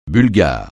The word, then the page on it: bulgare: francais